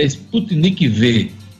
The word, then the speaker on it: Sputnik V: Entendimento entre o governo federal e o consórcio Nordeste sobre a Sputnik 5 comprova que dá para superar divergências políticas. Aliás, eu disse Sputnik 5, que é assim que se escreve, mas é Sputnik V, V de vacina, V de vacinação.